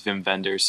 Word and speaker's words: Wim Wenders: I looked up the pronunciation, I said it was Wim Wenders, so...